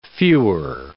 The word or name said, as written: fewer